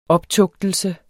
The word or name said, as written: optugtelse